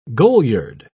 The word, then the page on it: goliard: British English